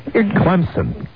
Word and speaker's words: Clemson: And, darling, you got to say it right. – Clemson. – Yes.